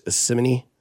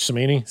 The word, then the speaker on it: Cimini: Rich Simini. C-I-M-I-N-I. – Rich Samini. – Samini.